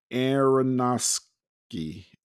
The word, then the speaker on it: Aronofsky: Yeah, Darren Aronofsky.